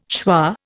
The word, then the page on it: schwa: Indian